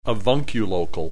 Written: avunculocal

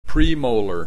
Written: premolar

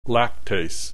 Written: lactase

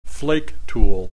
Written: flake tool

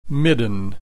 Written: midden

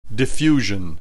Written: diffusion

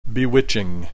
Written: bewitching

